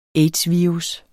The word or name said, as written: aids-virus